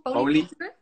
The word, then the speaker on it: polycultuur: Want je had het over niet eh, monocultuur, maar polo. Zeg ik het goed? – Polycultuur. polycultuur.